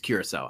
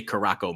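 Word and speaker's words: Curaçao: I know how to say blue Curacao, orange Curacao. It's Curacao. I used to say Caraco myself, like an idiot.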